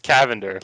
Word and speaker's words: Cavender: I was like, "It's Cavender, like lavender with a C."